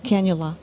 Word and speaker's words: cannula: Cannula.